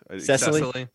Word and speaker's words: Cecily: I was just listening to um, uh, Cicely Strong. Am I saying her name right? – Cecily. – Cecily, Strong.